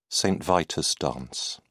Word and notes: Saint Vitus dance: British English

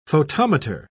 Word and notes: photometer: British English

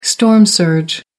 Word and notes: storm surge: American English

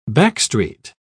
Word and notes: backstreet: British English

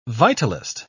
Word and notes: vitalist: British English

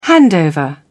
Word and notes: handover: British English

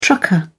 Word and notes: trucker: British English

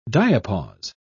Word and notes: diapause: British English

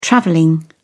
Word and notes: traveling: British English

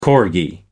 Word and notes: corgi: American English

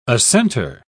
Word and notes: assentor: British English

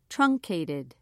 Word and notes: truncated: British English

